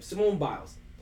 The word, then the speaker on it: Simone Biles: Like, so for people that don't know, Simone Biles, right? Am I pronouncing her name right? – Yes. – Right? Simone Biles, right?